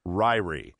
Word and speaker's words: Ririe: His last name is spelled R-I-R-I-E. So his name would be Dean Ryrie.